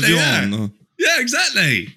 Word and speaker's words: Evian: It's Evian.